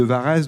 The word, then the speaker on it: Varetz: Varès, oui, vous habitez cette commune de Varès, dont le nom s'écrit V-A-R-E-T-Z. Mais on dit Varès.